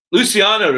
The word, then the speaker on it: Luciano: I always say his name wrong, uh, Luciano to come. I had it right. I should have just went with it.